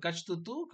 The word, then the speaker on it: Catch 22: Después por el campeonato junior, y ahora sí, como el show principal, ¿cómo se pronuncia esto? ¿Catch 2-2?